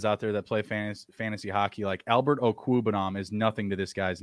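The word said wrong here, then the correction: Okwuegbunam: Like, and even I think I just said that wrong. It's Okwebunam.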